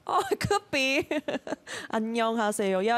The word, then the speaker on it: 안녕하세요: 안녕하세요. It's a 5-syllable word.